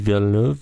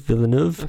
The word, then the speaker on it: Villeneuve: And the director, very good director, didn't, I'm going to butcher his name because it's French, Denis Villeneuve. Villeneuve.